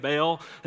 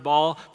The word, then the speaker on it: Baal: And you may have grown up your whole life saying it Baal. It's literally pronounced Baal, but you can say it any way you want.